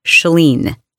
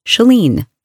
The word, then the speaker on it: Chalene: And all you have to do is answer a few questions, including how to pronounce my name. And it's Chalene, Chalene, Chalene.